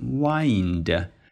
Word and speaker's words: wind: 然后关于那一个非常复杂的高级的中文说法婉言的鹅卵石道啊，其实很简单，就是 winding，这个词长得有点像风 wind，但是作为动词讲是读成 wind，就是 find，把那个 f 换成 w， wind， winding 表示的是曲折蜿蜒的。